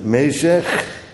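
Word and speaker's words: Mesech: Nou, dan beginnen we dus met Mezeg. Want zo willen ze dat wij het uitspreken.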